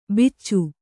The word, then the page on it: ಬಿಚ್ಚು: Kannada